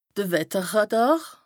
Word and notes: de Wetterràdàr: Bas-Rhin; Strasbourg

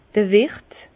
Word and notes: d'r Wiert: Alsatian; Bas-Rhin; Herrlisheim

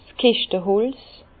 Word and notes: 's Keschteholz: Bas-Rhin; Herrlisheim

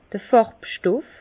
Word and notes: d'r Fàrbstoff: Bas-Rhin; Herrlisheim

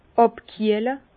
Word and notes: àbkiehle: Bas-Rhin; Herrlisheim

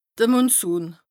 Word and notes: de Monsun: Bas-Rhin; Strasbourg

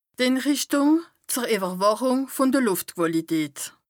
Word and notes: Inrìchtùng zer Ìwwerwàchùng vùn de Lùftquàlität: Bas Rhin

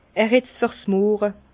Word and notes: er het fer's Murre: Bas-Rhin; Herrlisheim

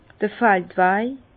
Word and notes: d'r Faldwaj: Bas Rhin; Herrlisheim

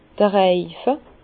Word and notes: d'r Reife: Bas-Rhin; Herrlisheim

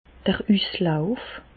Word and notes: d'r Üslauf: Alsatian; Bas-Rhin; Reichshoffen

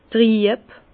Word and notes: trieb: Bas-Rhin; Herrlisheim

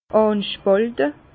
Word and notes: ànspàlte: Bas Rhin; Herrlisheim

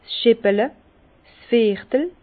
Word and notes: 's Scheppele: Bas-Rhin; Herrlisheim

